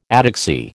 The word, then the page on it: ataxy: American English